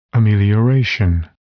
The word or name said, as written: amelioration